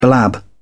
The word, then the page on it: blab: British English